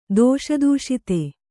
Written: ದೋಷದೂಷಿತೆ

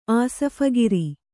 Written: ಆಸಫಗಿರಿ

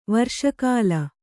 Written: ವರ್ಷಕಾಲ

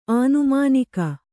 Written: ಆನುಮಾನಿಕ

